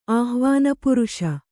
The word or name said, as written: ಆಹ್ವಾನಪುರುಷ